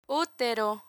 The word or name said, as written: utero